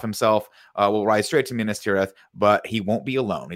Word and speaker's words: Minas Tirith: Is it I want to say this right. Minas Tirith? – That time you said it right. – Minas Tirith.